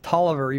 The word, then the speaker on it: Taliaferro: It's pronounced Tolliver, even though it's spelled Taliaferro.